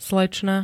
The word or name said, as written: slečna